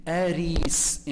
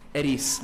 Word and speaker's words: Aris: Aris. In English, you would spell it A-R-E-E-S. Or if you want a really proper transliteration, A-R-I with the bar on top, S. Aris. Long vowel.